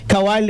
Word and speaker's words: Kawhi: Kawhi. Kawhi Leonard, el jugador de la...